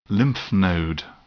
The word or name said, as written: lymph node